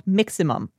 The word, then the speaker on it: Miximum: So one of the apps that I have been experimenting with recently is called Miximum. That's not me mispronouncing something. That's really what it's called. It's like Maximum, but with Mix at the start instead of Max.